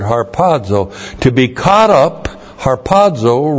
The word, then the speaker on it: harpazo: And it translates the Greek word harpazo. H-A-R-P-A-Z, long O.